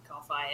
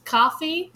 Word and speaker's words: Ko-fi: I think it's coffee because it has a little cup of coffee next to it, but it's spelled K-O-F-I. For those who haven't seen it before. That's why we're having such difficulty with this. It's not just, it's spelled like coffee and only has one ear.